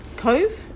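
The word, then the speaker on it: Cobh: So do you pronounce it Cove, in the English pronunciation? – Yeah. It was called Cove, C-O-V-E, before 1849, before August. And it's still called Cove. It's pronounced the exact same way, but it's C-O-B-H, the Irish abbreviation of it.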